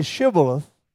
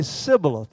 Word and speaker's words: Shibboleth: And so when they would come to the ford and they'd say Shibboleth, the Ephraimites would say Sibboleth. They would leave the H out.